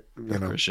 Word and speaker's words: Gyökeres: Like all the stories about Osimhen and Tony and the Swedish guy who was at Coventry, Gyökeres, is that how you pronounce it? – Gyökeres, yeah.